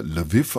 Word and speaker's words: Lviv: Lviv ist der ukrainische Name, auf Englisch wird er Lviv ausgesprochen. Die Stadt ist auch bekannt als Lemberg oder auf Polnisch als Lwów.